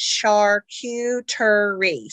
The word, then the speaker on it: charcuterie: So when you're saying charcuterie, it's charcuterie. So it's got four syllables. And I always just say Q, like give yourself a cue to do something. So that's okay. How I remember how to say it.